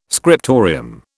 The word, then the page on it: scriptorium: British English